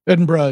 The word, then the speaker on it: Edinburgh: How do you say it? Yeah. Edinburgh is how it's pronounced.